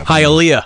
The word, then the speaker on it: Hialeah: This one was sent in by Brian Skinner at B-E-E Skinner, S-K-I-N-N-E-R. – Principal Skinner. – Halea, I think is how you pronounce it. – Oh. – Florida. – Oh, yeah. – Hialeah. – It's Hialeah.